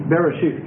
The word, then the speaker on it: Bereshith: That's spelled B-E-R-E-S-H-I-T-H. And what this word Bereshit means is in the beginning. Bereshit, excuse me. S, thank you. Like that. B-E-R-E-S-H-I-T-H.